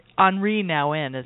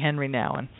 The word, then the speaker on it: Henri Nouwen: And we've Henri Nouwen is how you pronounce it, isn't it, Lori? – I think so. – Okay. Well, I'm saying Henry Nouwen. Sorry. – That's all right. It's offensive. – The Americanized version, and I'm sure he heard it a million times.